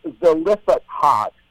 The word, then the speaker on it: Zelophehad: And in particular, we're going to chat about a very interesting episode known as the Daughters of Zelophehad. Am I pronouncing that correctly? – I don't know if there's one right, but I do the same way.